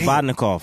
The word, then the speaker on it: Provodnikov: Provodnikov. – Provodnikov.